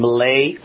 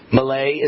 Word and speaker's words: Milei: The anti-socialist Javier Milei, I believe you pronounce it Milei. Milei is now the new president of Argentina.